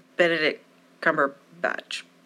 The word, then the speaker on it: Benedict Cumberbatch: but the one Benedict Cumberbatch.